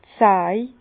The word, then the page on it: d' Saj: Bas-Rhin; Herrlisheim